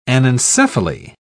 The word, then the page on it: anencephaly: British English